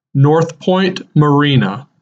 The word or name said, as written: North Point Marina